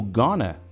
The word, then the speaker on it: going to: Já notou quando a gente usa o going to e fala rápido? Soa como gonna, né?